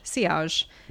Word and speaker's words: sillage: JK, it's French, sillage.